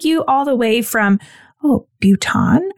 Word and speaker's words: Bhutan: It's pronounced Bhutan,